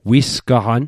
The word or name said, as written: wisgaqan